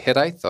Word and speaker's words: hiraeth: Is it hiraeth or hiraeth? – Hiraeth.